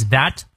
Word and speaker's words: that: What I've really learned，哎，really 注意它的长音，learned over time，I 双音 is that that 咬舌。